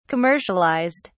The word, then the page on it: commercialized: British English